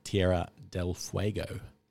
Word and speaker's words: Tierra del Fuego: And Nick has some great travel options coming up to Jurassic in Argentina, St. Brandon's off Mauritius, and the Rio Grande in Tierra del Fuego.